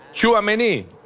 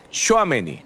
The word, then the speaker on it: Tchouaméni: Estamos diciendo mal. Entonces, nosotros decimos, Tchouaméni, no, eso no lleva el acento así, es Tchouaméni, no más.